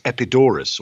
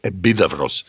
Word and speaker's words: Epidaurus: Another place is Epidaurus, or as the Greek pronounce it now, Epidavros, which is the famous theater.